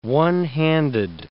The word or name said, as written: one-handed